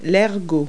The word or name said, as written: spur